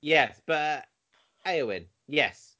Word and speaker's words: Eowyn: Eowyn.